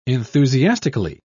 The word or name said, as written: enthusiastically